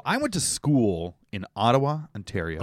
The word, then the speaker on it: Ottawa: And it's apparently pronounced Ottawa.